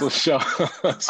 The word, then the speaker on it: Muscle Shoals: Muscle Shoals.